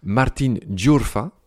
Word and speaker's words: Martin Giurfa: Alors, bonjour Martin, je me permets de te saluer en espagnol car tu es argentin, Martin Giurfa. – C'est ça. – C'est comme ça qu'on prononce ton nom.